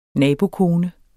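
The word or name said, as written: nabokommune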